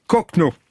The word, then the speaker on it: Kochno: C'est, ça se prononce comme un faux ami, Boris Kochno.